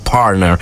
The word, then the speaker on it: partner: È giusta la pronuncia in inglese, partner.